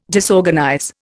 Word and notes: disorganize: Indian

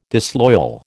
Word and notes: disloyal: American English

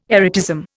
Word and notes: erotism: Indian

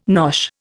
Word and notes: nosh: Indian

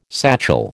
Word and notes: satchel: American English

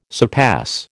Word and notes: surpass: American English

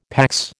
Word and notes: PAX: American English

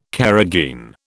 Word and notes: carrageen: British English

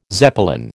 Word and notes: Zeppelin: American English

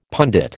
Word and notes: pundit: American English

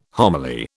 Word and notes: homily: British English

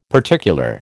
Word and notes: particular: American English

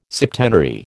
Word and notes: septenary: American English

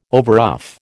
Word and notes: overruff: American English

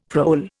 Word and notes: prole: Indian

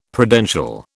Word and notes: Prudential: British English

